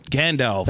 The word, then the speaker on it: Gandalf: He's like Gandalf.